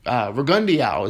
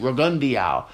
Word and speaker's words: Rigondeaux: And in Lomachenko's last fight against, now it's funny, it was Rigondeaux is how it's being pronounced now on TV. Jim Lampley, I'm going to rely on Lampley to say Rigondeaux.